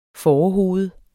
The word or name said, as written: fårehoved